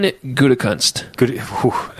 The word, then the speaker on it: Gutekunst: Brian Gutekunst. – That's a name. Gutekunst. That actually kind of flows. Gutekunst. – But here's, I don't know really much about Brian Gutekunst.